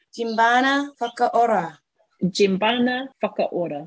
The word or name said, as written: Djeembana Whakaora